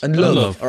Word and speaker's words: Ndlovu: Is that how would you say it? N-D-L-O-V-U. – NDL, steady, Dave. Not with your previous comments. – Ndlovu. All right, like Peter. Ndlovu.